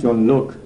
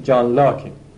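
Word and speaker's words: Locke: این چیز تلفظ فرانسویشه، تلفظ انگلیسیش جان لوک. لرد على دکارت، یعنی معمولا ایستان و عربا همینجور میمیسن، چون اویی که در ما مثلا آ تلفظ میکنیم یه شباهتی هم به او داره دیگه، عربا اینا رو غالبا به این صورت میمیسن، ولی تلفظ فارسی شایعش جان لاکه.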